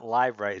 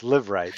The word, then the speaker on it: Liveright: It's it's not live right. It's live right.